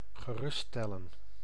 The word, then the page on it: rassurer: [ʁa.sy.ʁe]; France